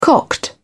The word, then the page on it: cocked: British English